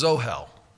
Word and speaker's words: ohel: M-I-S-H-K-A-N, mishkan. The word for tent is ohel. O-H-E-L is the way we would transliterate it.